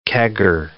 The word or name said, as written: kegger